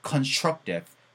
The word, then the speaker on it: constructive: TV가 아니고 TIF, TIF, TIF가 되니까 constructive, constructive, constructive, constructive, constructive, constructive 이렇게 부드럽게 연결될 거예요.